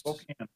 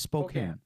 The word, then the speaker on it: Spokane: Is it Spokane or Spokane? I'm on the East Coast. – Spokane. – It is Spokane. Okay. Is that a heavily debated thing? Like, do people often mispronounce that, or is it not? Is that not? Am I just an asshole? – It's often mispronounced, I would say.